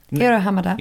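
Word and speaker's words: Hiro Hamada: Hero Hamada. – Yeah, equal emphasis on all syllables.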